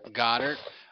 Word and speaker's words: Goedert: Is that how you say that? South Dakota? – Goddard.